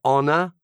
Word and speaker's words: honor: Una cosa interessante è quanto è importante l'H prima di tutto, perché se una parola inglese c'ha l'H all'inizio, ci sono delle eccezioni dove c'è scritto l'H ma non si pronuncia, ma sono tipo tre. C'è honor, hour, che sarebbe ora, e poi c'è... Robert, what's the other one? There are three, right? Three words in English. – Honest. – Honest, honest. Ecco, onesto.